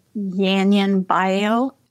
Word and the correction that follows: Yunnan Baiyao: Uh, Yunnan Baiyao.